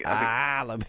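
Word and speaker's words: Alebiosu: Is that how you have to say his name, by the way? – Alibi. yeah, I think that's how you've got to warm up.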